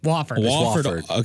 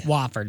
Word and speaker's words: Wofford: Wofford. – Okay. – Wofford.